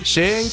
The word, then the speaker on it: Chink: Prononce comme ça. Chink. C'est ça ? Chink. – Chink. – Hein, c'est juste Chink, oui, c'est ça.